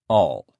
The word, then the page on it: all: male